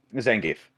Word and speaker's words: Zangief: How do you say that character's name? – Zangief. – Zangief. – That's what it is.